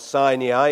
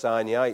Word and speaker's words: Sinai: It was absolutely hammered into us at theological college that it is not pronounced Sinai, it's Sinai. We were given all the reasons for it. I find it hard to call it Sinai now, because there's no AI, it's AI, it's Sinai. So